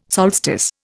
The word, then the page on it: solstice: Indian